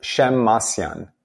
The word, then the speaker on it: Shemmassian: Yeah, so my last name is pronounced Shemmassian.